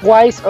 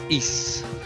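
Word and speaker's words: Ys: Porque yo le digo Wise Origins, pero aquí me sugirieron decirle Is Origins. – ¿Cómo se pronuncia? Yo digo que es Is.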